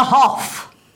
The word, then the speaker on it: Haulgh: The H-A-U-L-G-H. How on earth do you pronounce that? The Hoff.